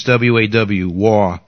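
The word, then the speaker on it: Vav (וָו): No, it's W-A-W, Waw, but we pronounce that Vav nowadays. Then we go to Zion, which is the letter 7. You know, Vav is a 6th letter.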